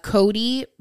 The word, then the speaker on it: Kodye: So her Instagram is Cody, but it's spelled K-O-D-Y-E.